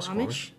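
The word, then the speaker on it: Suquamish: Alright, so another Squamish, I think that's how you pronounce it. It's S-U-Q-U-A-M-I-S-H. – Yeah, that sounds about right. Squamish.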